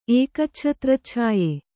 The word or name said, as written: ಏಕಚ್ಛತ್ರಚ್ಛಾಯೆ